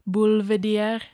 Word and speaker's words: Boulevardier: It would be like How do you say boulevard in French? I think it's this. – Boulevard. Boulevardier.